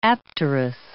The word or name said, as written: apterous